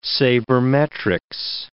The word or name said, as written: sabermetrics